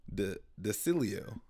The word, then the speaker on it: DiCillo: No, directed by Tom DiCillo is how you pronounce it. – Oh, my God. That was That's real. Fun. – Tom. – It's spelled like D-I-C.